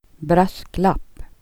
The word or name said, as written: lapp